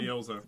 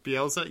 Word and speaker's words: Bielsa: Bielsa. – Bielsa, yes, thank you.